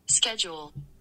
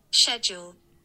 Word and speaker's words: schedule: Schedule. – Right. So there you go. See how the American is like the K, the hard K, schedule, or the hard C, the K in there.